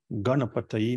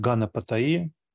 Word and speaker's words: Ганапатай: Известна его мантра, самая простая, да, Муа мантра, Гам Ганапатай Намаха, Экакшари мантра, Ганапатай, только надо правильно произносить, там Га – это горловая такая, Гам, Га, На, это вот язык чуть назад проворачиваем, кончик На, ну, вверх, серебральный звук На, носовой, Ганапатай Намаха, потому что некоторые говорят Ганапатай, ну, это… Бывают ошибки в произношении еще более серьезные, да, там.